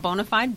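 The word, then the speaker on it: Bona5d: it's spelled B-O-N-A, the number 5-D. It is pronounced bonafide,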